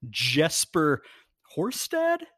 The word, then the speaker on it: Jesper Horsted: Tight end Jesper Horsted? Am I saying that right? – Yeah.